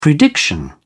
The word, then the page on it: prediction: British English